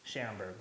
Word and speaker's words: Sharenberg: We have Cortney, without a U, Sharenberg.